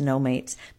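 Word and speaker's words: Nomates: So Billy No Mates did a song called No just before that, and I do know now that her name is pronounced No Mates,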